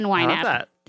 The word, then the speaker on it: YNAB: So I stan YNAB.